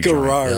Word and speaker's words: Garrard: I keep thinking about maybe starting a barbecue Garrard. – Joint. Yeah. Yeah, no, yeah. – The hard G.